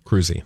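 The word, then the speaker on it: Kroeze: Cruzy. – Okay. And it's spelled weird. It's on my t-shirt. It's K-R-O-E-Z-E.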